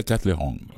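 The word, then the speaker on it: Katlehong: Moi, je le prononce comme l'agent de la compagnie le prononce. Il dit Via Kettle Hong. – Via Kettle Hong, dance.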